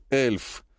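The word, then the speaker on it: elf: «Потому что 11 по-немецки произносится как «эльф», — улыбнулся Сергей и продолжил.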